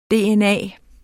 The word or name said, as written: dna